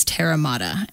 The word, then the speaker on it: Terra Mater: So when the name Terra Mater came to me, the pronunciation that I've chosen is Terra Mater, and Terra, T-E-R-R-A. Mater is M-A-T-E-R.